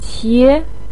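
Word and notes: qie2: tone 2

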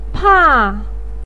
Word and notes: pa: 4

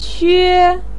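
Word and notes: que1: tone 1

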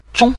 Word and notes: zhong: zhong5

